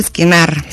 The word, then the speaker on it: Скенар: И ударението специално го слагам на АТО, за да направя разликата между скенер, което е диагностична апаратура, и скенар, което е електронен апарат за въздействие върху акупунктурни точки, меридиани, зони.